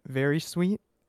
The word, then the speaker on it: Varysuite: I go by the musical artist name Very Sweet. It's phonetic, but it's spelled V-A-R-Y-S-U-I-T-E.